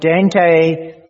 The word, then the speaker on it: Dante: Friends, I'm sure that a number of you at some stage or another have seen pictures and copies of the artist Dante, that's how you pronounce his word, and his famous painting on hell. If you've had a look at that, you will remember that Dante takes us in that painting right down into the very lowest pit of hell.